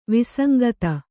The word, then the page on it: ವಿಸಂಗತಿ: visangati